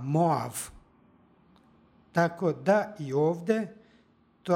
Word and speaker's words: Moav: stiha, pročitali smo Moav. Ovdje bi slovo as, posle vokala, trebalo da ima izgovornu vrednost ja. Međutim, u srpskom jeziku ovdje izgovaramo a, Moav.